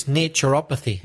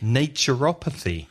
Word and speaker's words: naturopathy: How would you say that? – I think it's naturopathy. – Naturopathy. Yeah, that sounds good.